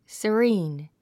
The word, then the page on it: serene: səríːn; サリーン